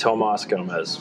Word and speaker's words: Tomas Gomez: Do you do the American pronunciation? How do you tell? – It's Tomas Gomez.